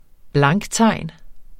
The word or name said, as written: blanktegn